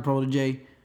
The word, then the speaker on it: Protege: it's Protege, like, J, just like that, like, you get, I don't give a fuck if you even say J, just straightforward, that sounds close enough, but it's not Prodigy.